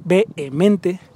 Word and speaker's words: vehemente: hay una H, vehemente eh, ahí donde dice separador es la H muda.